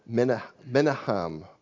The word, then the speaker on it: Menahem: Menahem, or Menahem, which would be the Hebrew pronunciation.